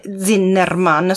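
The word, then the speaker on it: Zinnemann: Zimmerman, che Zinnerman, scusate lo pronuncio sempre male, che si chiamava, era insomma Mezzogiorno di Fuoco, insieme al suddetto eh, Gary Cooper.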